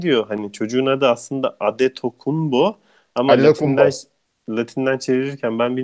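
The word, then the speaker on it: Antetokounmpo: Bunu Latin, Yunan alfabesinde D harfi olmadığı için NT yan yana gelince D oluyor. MP yan yana gelince de B okunuyor. Öyle tercih ediliyor. O yüzden Adetokunbo doğrusu.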